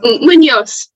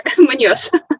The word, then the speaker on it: Muñoz: Muñoz. – Muñoz. – It's there. Muñoz.